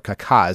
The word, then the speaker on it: Kaká: Kaká is how you say it, not Kaká, but Kaká.